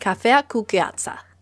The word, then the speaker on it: cafea cu gheață: Iced coffee is pronounced cafea cu gheață.